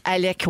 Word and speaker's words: Alec: Alex Baldwin. Alec, oui.